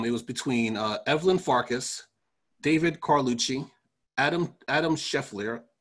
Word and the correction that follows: Schleifer: S-C-H-L-E-I-F-E-R. I'm pretty sure I butchered this poor person's name. – S-C-H-L what? – S-C-H-L-E-I-F-E-R. – Yeah, that sounds like Schleifer.